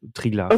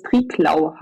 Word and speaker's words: Triglav: Im Triglav Nationalpark, würde ich jetzt auch so sagen. Also Triglav. – Okay. Ich werde manchmal unter YouTube-Videos nämlich berichtigt, dass es Triglau heiße. – Okay. – Also ausgesprochen. Aber ich nenne es auch Triglav.